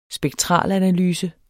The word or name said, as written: spektralanalyse